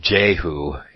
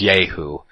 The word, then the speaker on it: Yehu: And Yehu is pronounced Jehu in the King James Version, or it's spelled out Jehu, but it's Yehu if you go to the pronunciation part of your esword, because there's pronunciation with all of the words.